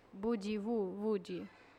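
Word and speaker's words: Boogie Woogie: Ma mi sa che si pronunciava Bugi Wugi.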